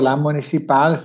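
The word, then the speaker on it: La Municipàl: Eh, bene, eh, come dicevo, la municipal si pronuncia alla francese.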